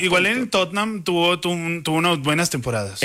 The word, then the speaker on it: Tottenham: Enséñanos, porque es que esa Tottenham. Tottenham. Son dos sílabas.